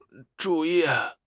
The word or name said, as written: zhu4 yi4